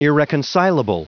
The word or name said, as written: irreconcilable